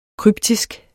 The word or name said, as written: kryptisk